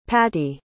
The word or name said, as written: patti